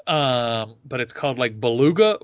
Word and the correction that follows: bulgogi: beef. Bulgogi? Yes, thank you very much.